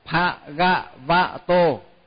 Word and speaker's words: Bhagavato: Cái chữ đó là nhiều người hay đọc nhầm lắm. Nhiều khi là Bhagavato, tức là đọc thật á, mà quen rồi bây giờ cứ đọc hoài rồi, phải đọc, phải phát âm cho thật đúng là Bhagavato.